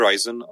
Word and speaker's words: Ryzen: To jsem, ačkoliv já se teda tady tomu nevěnuju jako vůbec, ale tohle to jsem taky zaznamenal, že ten Ryzen je tady v tom odvětví docela dost chválený teď. – Když tak asi se to vyslovuje Ryzen, ale to je detail.